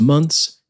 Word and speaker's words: months: Everybody just says months, and you should too, and certainly never months's.